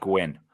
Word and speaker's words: Gwynne: Fred Gwynne, I think is, is it's Fred, but I think it's pronounced Gwynne. Yep.